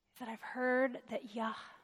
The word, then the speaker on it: Yahweh: Y-H-W-H. There's debate about those vowels. The vowels were not written down. But earliest manuscripts where there was some attempt at trying to show the pronunciation, that's why they've included the A and the E, like a Yahweh. So that's probably the closest to what was said.